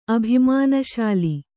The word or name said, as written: ಅಭಿಮಾನಶಾಲಿ